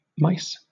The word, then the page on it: mice: British English